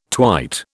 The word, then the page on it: twite: British English